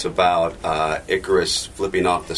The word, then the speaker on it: Icarus: Did I pronounce it right that time? – You did. I was like, what are you doing? – I should pronounce it like Ike Turner. – Icarus. Icarus.